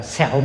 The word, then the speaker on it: xẻo: Thực ra cái chữ này phải đọc là xẻo mũi.